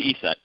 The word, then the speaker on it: ESET: Did I pronounce that right? Is it ESET, or how is it actually pronounced? – Yeah, it's definitely ESET.